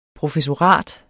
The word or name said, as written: PROFESSORAT